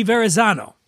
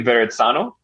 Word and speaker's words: Verrazzano: Uh, I would think with a double Z, does mozzarella have two Z's? So maybe Verezzano.